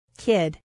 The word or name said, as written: kid